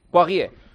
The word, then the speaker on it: Poirier: En baloncesto, el Madrid ha hecho oficial el fichaje del pívot francés Vincent Poirier. ¿Lo he dicho bien? – Perfecto. – ¿Sí? – Poirier.